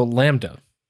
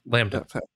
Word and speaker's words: LaMDA: So Lambda. – Is that how you say it? I don't know how you say this. – Yeah, Lambda. – Yeah, Lambda.